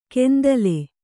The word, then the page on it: ಕೆಂದಲೆ: kannada